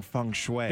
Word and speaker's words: feng shui: I'll celebrate my small victory earlier in the show saying feng shui. – That is correct.